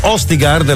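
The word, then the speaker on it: Østigård: Nico Østigard, la pronuncia è giusta? – Sì.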